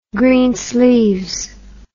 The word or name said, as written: Greensleeves